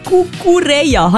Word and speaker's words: Cucurella: Der Cucurella. Habe ich es richtig ausgesprochen? Ist richtig, der Marc Cucurella.